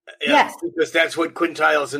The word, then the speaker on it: quintiles: Also in February from Rick, we're going to be having the long awaited septiles and quintiles class. Am I saying that right? Septiles, quintiles.